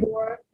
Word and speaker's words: Sobibor: Sobibor, Belzec, and Treblinka.